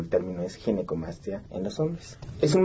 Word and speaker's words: Ginecomastia: Ginecomastia. – Sí, exacto. Ginecomastia, ok.